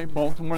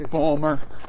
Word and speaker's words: Baltimore: They don't say Baltimore. They go Balmer.